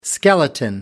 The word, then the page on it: skeleton: American English